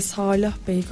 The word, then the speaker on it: Salah: Salah Bey diye okunuyor galiba ya. Onu bir tekrar teyit edeceğim. İkinci A uzun diye biliyorum.